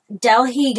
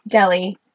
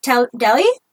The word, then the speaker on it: Delhi: These have included the 2012 Delhi gang rape. I'm probably saying that wrong. My apologies. – Delhi. – Delhi? Okay, Delhi gang rape. Thank you.